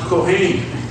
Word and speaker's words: Kohen: Cohen. In Hebrew, it's pronounced Kohen.